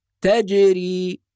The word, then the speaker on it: تجري: الكلمة التي بعدها تجري، وبعض الناس ينطق الجيم بدون قلقلة فيقول تجري أو تجري بالجيم، وهذا خطأ، وإنما الصواب تج، الجيم الصحيحة من وسط اللسان كما ذكرنا في الحلقات السابقة، أج تج مع القلقلة، فنقول تجري وهي تجري بهم في موج كالجبال.